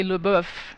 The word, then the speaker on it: LaBeouf: Everyone says Shia LaBeouf, but it would be LeBeouf, like en français. – Well, anyway... – Je ne parle pas français. – I know. – Shia LaBeouf is in the new Lars von Trier film.